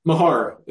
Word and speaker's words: Mahar: Mahar. It's not phonetic.